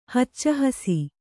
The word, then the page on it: ಹಚ್ಚಹಸಿ: hacca hasi